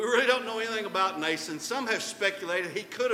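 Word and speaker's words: Mnason: This guy's name, the M is silent here, like the X in cucumber. Nason, and we don't really know anything about Nason.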